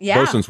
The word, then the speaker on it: boatswain: The boatswain's whistle. – Bosun's whistle.